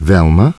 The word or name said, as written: Velma